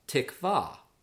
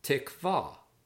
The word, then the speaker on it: tikvah: Word 37 means hope or cord, and it's pronounced tikvah, tikvah.